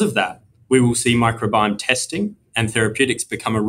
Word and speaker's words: microbiome: I said microbiome science, and I was hoping that I had pronounced that correctly. If I'm not sure how to pronounce it, I certainly don't quite understand what it is. Please explain. – I think you did a great job pronouncing that.